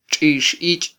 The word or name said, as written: txixich